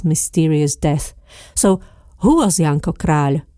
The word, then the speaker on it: Janko Kráľ: Try to say Janko Kráľ. Many Slovaks pronounce the surname of the romantic poet incorrectly. Kráľ is not the same as Kráľ, which means king in Slovak.